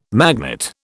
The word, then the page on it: magnate: British English